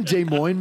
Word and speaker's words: Des Moines: Did you meet him in Des Moines, man? You want a White Claw? – Hey, you said it right.